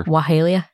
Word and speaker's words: Oxalaia: You were just saying Oxalaia, although you pronounced it probably better. – Wahalia? – Yeah.